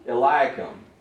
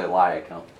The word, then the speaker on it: Eliakim: Eliakim. – I like the way you pronounce it better than the way I pronounce it.